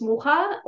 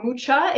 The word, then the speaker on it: Mucha: Everybody knows of Alfons Mucha, or Mucha, if you pronounce it wrong.